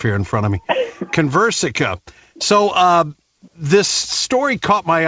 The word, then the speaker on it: Conversica: Our next guest is with, and I hope I'm saying this right, Conversica. Is that right, Terry? Terry is with us. – It's Conversica, but very close. – Conversica. Well, it wasn't even close.